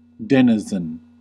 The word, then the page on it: Bewohner: /bəˈvoːnɐ/